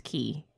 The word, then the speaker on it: Quay: Which is interesting because the word that I thought was pronounced Quay is actually pronounced Key. Q-U-A-Y.